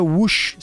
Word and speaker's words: Woosh: O nome da empresa é Wush. Se escreve W-O-O-S-H.